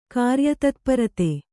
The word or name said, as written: ಕಾರ್ಯತತ್ಪರತೆ